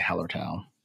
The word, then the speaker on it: Hallertau: I've heard that pronounced different ways, but I'm going to say Hallertau.